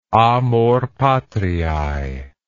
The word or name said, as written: amor patriae